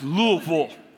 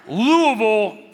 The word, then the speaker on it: Louisville: I was in Kentucky last week, Louisville, Kentucky, not Louisville. All right, these people drank so much bourbon, they can't even pronounce their city, all right? It's not Louisville, it's Louisville, all right? Louisville,